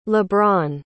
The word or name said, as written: lebron